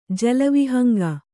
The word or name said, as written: ಜಲವಿಹಂಗ